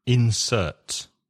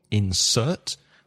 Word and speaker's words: insert: Insert. When it's a verb, you stress the second syllable, insert. When it's a noun, you stress the first syllable, insert.